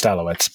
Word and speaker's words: Alouettes: She pronounces it the Alouettes or Alouettes. – Yeah. – It's just Alouettes, but yeah, she gets it correct.